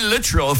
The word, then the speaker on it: Littrell: Brian Littrell from the Backstreet Boys. Yeah, that's how it's pronounced.